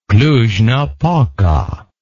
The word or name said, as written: Cluj-Napoca